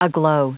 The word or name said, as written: aglow